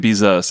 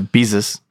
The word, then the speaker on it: Bezos: He's saying Bezos, as in like Jesus. – Yeah, Bezos.